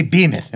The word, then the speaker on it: Behemoth: Because I was going to make a comment about, since we're in America, we normally don't pronounce the H in the title. But the English do. – That is... – Now, what do the English say for that? – Behemoth. – Okay. Behemoth.